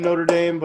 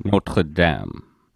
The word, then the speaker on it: Notre Dame: Like, you would pronounce the college football team Notre Dame, but... – Notre Dame and the French one, Notre Dame.